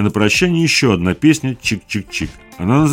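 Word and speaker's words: !!!: А наш обзор завершает диско-панк-ансамбль с танцевально-авангардным уклоном из калифорнийского города Сакраменто, название которого состоит из трех восклицательных знаков и произносится как «чик-чик-чик».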